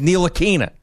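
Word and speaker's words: Ntilikina: And then Mike Breen went to him and said, how do you want me to pronounce it on the air? And he says Nalikina. So that's why we're going with Nalikina now.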